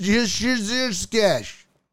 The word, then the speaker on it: Juszczyk: It's pronounced Juszczyk.